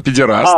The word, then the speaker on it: педераст: А, ну, в смысле, то есть это классическая Пелевинская, да, тот самый пидорас... – Подождите, мы когда говорим вот это слово, мы его произносим литературно. Педераст.